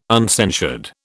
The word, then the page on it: uncensured: British English